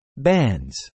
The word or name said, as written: bans